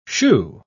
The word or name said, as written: shoo